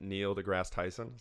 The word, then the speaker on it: Neil deGrasse Tyson: So it was from a podcast that Neil deGrasse Tyson, is that how you say his name? – Mm-hmm.